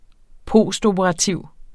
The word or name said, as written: postoperativ